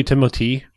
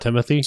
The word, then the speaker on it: Timothée: So it is really Timothée. It's not Timothy? – Yes. Okay. Because it's French.